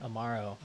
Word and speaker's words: amaro: Amaro. Am I saying that right? – Amaro. – Yeah.